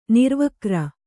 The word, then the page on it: ನಿರ್ವಿಕಾರಿ: nirvikāri; Kannada